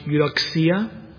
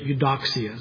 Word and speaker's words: Eudoxia: I've heard her name pronounced Eudoxia, but I've learned it Eudoxia, so I'm going to pronounce it that way, please.